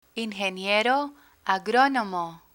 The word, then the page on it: ingeniero agrónomo: Spanish